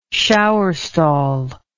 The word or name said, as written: shower stall